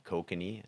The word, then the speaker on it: Kokanee: And yeah, Kokanee is, yeah, spelled like K-O-K-A-N-E, Kokanee, something like that.